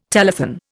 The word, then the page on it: telethon: Indian